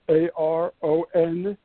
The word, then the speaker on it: Aronson: My last name is spelled Aronson.